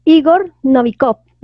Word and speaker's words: Igor Novikov: En esta teoría nos menciona, se basa en el principio de la autoconciencia y la apoya el físico ruso Igor Novikov.